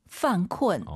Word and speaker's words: 犯困: 犯困犯困 이 발음을 잘하셔야 됩니다. 쿤쿤 하지 마시고 유엔 사이에 으어 들어가서 다시 한번 犯困.